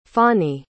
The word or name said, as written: fani